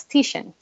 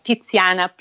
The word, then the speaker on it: Tiziano: Um, and uh, but most Americans or English-speaking people know him as Titian, T-I-T-A-N, because uh, there is so much inhibition about pronouncing the the Z. So it's like Tiziana, pizza, pazza, piazza, and so it's um, yes, here that's that's my mission in life.